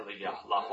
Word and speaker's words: La Jolla: How do you say it? La Jolla. Watch my English now, guys. Raised in La Jolla, California, and often referred to as Felix or Big J.